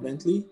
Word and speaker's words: Vently: So today we're going to learn all we can about Vently.